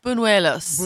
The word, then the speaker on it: buñuelos: Buenellos. – Say it right. – Buñuelos.